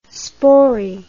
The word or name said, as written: spori